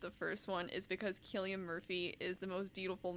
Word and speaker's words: Cillian: I didn't know it was pronounced Cillian, wow. Yeah. I have been pronouncing it Cillian for... – Yeah. I did too for years, and then I found out it was Cillian, and I felt like an idiot.